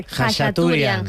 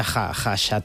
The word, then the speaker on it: Khachaturian: Khachaturian. – Khacha. – Aram, se escribe K-H-K-H-A, pero se pronuncia Khachaturian.